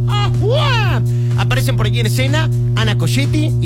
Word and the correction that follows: Ciocchetti: Isabella Camil, Ana Ciocchetti, perdónenme, lo dije mal.